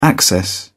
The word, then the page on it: access: British English